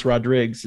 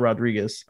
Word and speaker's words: Rodriguez: You know, my last name is pronounced Rodriguez. It's not Rodriguez. – Okay. – It's, you know, Portuguese Hawaiian.